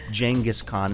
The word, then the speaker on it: Genghis Khan: On the Bryant Park Progress, he will be and forever will be known as Genghis Khan. And if you don't like it, sorry. Robert Siegel says that's how you say it.